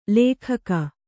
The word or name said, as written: ಲೆಖ್ಖ